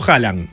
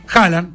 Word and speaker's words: Haaland: Yo digo Haaland. – Haaland, perfecto. Haaland. Pero lleva más de una.